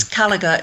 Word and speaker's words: Kalaugher: I hope I'm pronouncing your last name correctly. It's K-A-L-A-U-G-H-E-R. It could be. – That's it. It's Callagher.